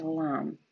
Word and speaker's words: lam: Mantra koja se izgovara kako bi se aktivirala prva korenska čakra jeste lam. I izgovara se dugo.